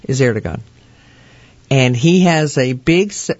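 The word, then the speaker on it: Erdoğan: And then you have Turkey, Erdogan. Is that how you say his name? – Erdogan. – Erdogan. Yeah.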